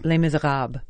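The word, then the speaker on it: Les Misérables: Les Miserables.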